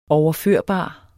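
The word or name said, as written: overførbar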